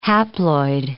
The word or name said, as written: haploid